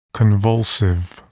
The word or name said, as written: convulsive